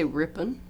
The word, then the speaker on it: Ripon: Ripon, R-I-P-O-N. Wisconsin. – I'd say Ripon.